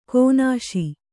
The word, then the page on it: ಕೀನಾಶ: Kannada; kīnāśa